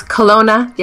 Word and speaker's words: Kelowna: And how do you pronounce that name? It's Kelowna? – It's Kelowna, yeah.